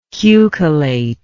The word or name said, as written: cucullate